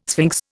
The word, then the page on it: sphinx: Indian